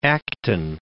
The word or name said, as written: Acton